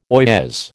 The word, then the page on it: oyez: American English